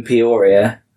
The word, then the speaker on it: Peoria: It was in the episode Good Morning Peoria.